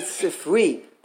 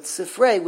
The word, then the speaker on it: sifrei: If you would say sifri, that would refer to one book, but when you pronounce it sifrei with its tsere, it means two books.